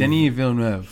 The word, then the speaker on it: Villeneuve: Is it Denis Villeneuve. – Villeneuve.